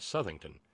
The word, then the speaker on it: Southington: Darlene, with an editorial note, says, "We pronounce it Southington, not like South as in Northeast and West."